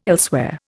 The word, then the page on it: elsewhere: Indian